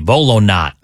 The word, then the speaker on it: Volonaut: It's V-O-L-O-N-A-U-T. Volonaut.